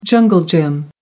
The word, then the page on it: jungle gym: American English